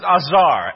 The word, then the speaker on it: azar: The word for help is another word, and we pronounce it azar. A-Z-A-R.